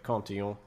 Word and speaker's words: Cantillon: Well, I suppose you should pronounce it the French way, Cantillon, maybe.